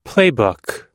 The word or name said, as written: playbook